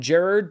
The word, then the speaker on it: Gerard: Also hitting theaters this weekend is the newest film from Gerard, that's how I say it because that's how it's pronounced, Butler, Kandahar, about Butler and his translator fighting for survival.